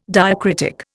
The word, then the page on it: diacritic: Indian